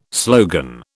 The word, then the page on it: slogan: British English